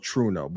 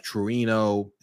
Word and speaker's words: Trueno: So I said, like, it's called Truno, but it's spelled T-R-U-E-N-O, which is like Truino.